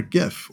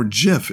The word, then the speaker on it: GIF: You could, you know, drop a meme or a GIF or GIF if you're a crazy guy who pronounces the G as a soft G.